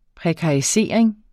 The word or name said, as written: prekarisering